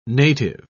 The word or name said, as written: native